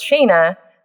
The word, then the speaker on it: Shayna: But Nigel was our designer and he couldn't say my name because my name's pronounced Shayna. So in England, everything has an R after certain sounds. So he would call me Shayner.